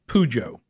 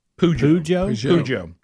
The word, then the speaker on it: Peugeot: So she says you pronounce it Peugeot. – Peugeot. – P-E-U-G-E-O-T. Peugeot.